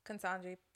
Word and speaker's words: Ketanji: Now, by the time we get to the 50th Ketanji Brown Jackson, then am I saying that right? – Ketanji Brown Jackson. – Yeah. Okay. – Judge Ketanji, yes.